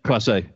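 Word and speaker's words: Clase: Is it Emmanuel Classe? – Yes. Classe.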